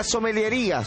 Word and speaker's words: sommeliería: La señorita Silvia Barton, o señora Silvia Barton, va a estar en general en hablándonos de maridaje y otras cosas referido a la sommeliería. ¿Se dice así? – Muy bien.